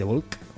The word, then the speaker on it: Bulk: Que se llama Cracking of the Bull. The Bulk, perdón, con la K, no la he pronunciado.